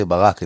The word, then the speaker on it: Barak: When I say Barak and they say, what? I say, okay, Barack Obama without the Obama.